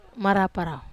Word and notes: marapa rau: wamesa

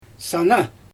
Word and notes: sana: Xyzyl

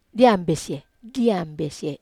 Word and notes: ambesie: Wamesa

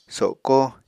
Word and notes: soko: Apatani

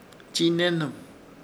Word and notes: Chiinehnɨm: Ishir Chamacoco

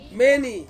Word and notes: meni: Ansus

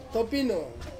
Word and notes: topino: Ansus

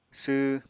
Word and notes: sww: apatani

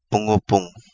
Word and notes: Pungupungu: Kapingamarangi

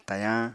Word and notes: tayañ: apatani

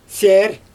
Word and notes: sʲer: Xyzyl

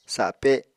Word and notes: sape: apatani

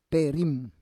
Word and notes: perim: Roon

